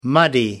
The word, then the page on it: muddy: American English